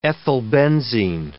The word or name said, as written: ethylbenzene